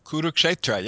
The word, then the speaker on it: Kurukshetra: So you're dealing with a text that's connected to an identifiable geographical location, and correct me if I pronounce this wrong, but Kurukshetra? – Kurukshetra, yes.